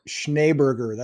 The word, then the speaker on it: Schneeberger: Well, you said it the way it's spelled, but it's actually pronounced Schneeberger. That's what my dad told me. – Schneeberger. – There's two E's in there, but they're pronounced like an A-Y.